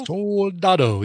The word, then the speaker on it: Soldado: Sicario, Day of the Soldado. Soldado? – Soldado, yes. – I guess is how you say it.